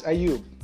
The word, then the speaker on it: Ayub: My name is Ayub. It's an Arabic name.